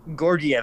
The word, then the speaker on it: Georgiev: I don't want to butcher his name, but I believe it's Alexander Georgiev. – Georgiev, that's pretty good, Chris.